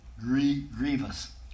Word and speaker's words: grievous: Grievous. It's pronounced grievous. Sometimes people pronounce it grievous. I think that's fun.